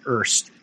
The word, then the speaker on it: Hearst: This is way, you know, years before I met Alexa, and she had family in Erst, H-E-A-R-S-T, but it's pronounced Erst, which is a 10.5 mile, 120 kilometer per hour drive directly north.